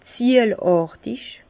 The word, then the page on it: ziejlàrtig: Bas-Rhin; Herrlisheim